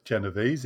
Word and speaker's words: Genovese: Kitty Genovese, or Genovese, if you want the New York pronunciation, was 28 years old when late one evening she was arriving back at her home.